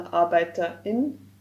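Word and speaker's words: Arbeiter*in: It's pronounced with a short pause, for example, Arbeiterin, and it includes all genders,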